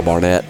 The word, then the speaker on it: Barnett: I've got a decent Instagram page that I keep updated pretty regularly, just my name, Lyle Barnett. Last name is spelled B-A-R-N-E-T-T.